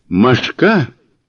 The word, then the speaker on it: машка: «Машка» произносится непременно с ударением на последнюю букву, пострашнее любого зверя, еще и потому, что способна довести любого таежного зверя до безумия.